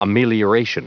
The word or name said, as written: amelioration